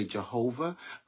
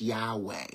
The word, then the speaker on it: Yahweh: Uh, many times people say Jehovah, uh, but there really are no J's in the Hebrew language. So it's Yahweh, or some people pronounce it Yahweh.